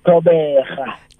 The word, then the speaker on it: Thobega: It's Thobega.